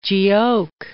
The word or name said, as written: Giauque